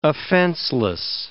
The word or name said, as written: offenseless